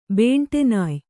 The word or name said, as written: ಬೇಂಟೆನಾಯ್